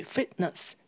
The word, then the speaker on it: fitness: 所以 fitness 也可以指健康或是指健身。请留意这个 t 常常会念成 stop t，也就是它阻断气流，不会把 t 念出来，但是会有一个小小的停顿音，这样念起来会比较顺。